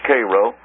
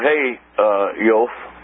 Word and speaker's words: Chayot: Now, the Cairo, C-H-A-Y-O-T, pronounced Cairo, or Hayyof, depending on which dialect you're speaking, is a command named after their chief, Kea.